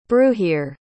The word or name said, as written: bruhier